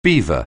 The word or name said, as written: beaver